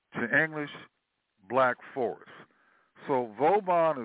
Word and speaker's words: Vauban: And there's another community that it's called Vauban, V-A-U-B-A-N, or some people pronounce it Vauba.